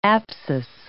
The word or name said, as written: apsis